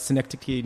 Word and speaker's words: Synecdoche: Adaptation, Synecdoche, New York.